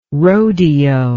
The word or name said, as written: roadeo